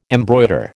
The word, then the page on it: embroider: American English